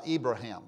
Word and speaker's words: Ibrahim: Ibrahim.